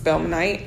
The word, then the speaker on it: Spelmanite: I think I said that right because my homegirl's a Spelmanite. – Mm-hmm. – So yeah, you did.